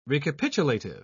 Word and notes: recapitulative: British English

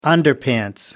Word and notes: underpants: American English

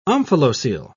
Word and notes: omphalocele: British English

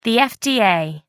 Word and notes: fda: American English

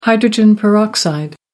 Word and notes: hydrogen peroxide: American English

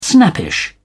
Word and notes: snappish: British English

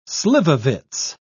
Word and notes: slivovitz: British English

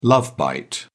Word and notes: love bite: British English

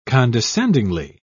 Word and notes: condescendingly: British English

